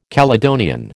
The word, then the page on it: Caledonian: American English